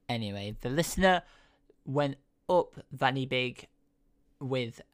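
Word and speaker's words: Fan y Big: the mountain, which is pronounced Vanny Big.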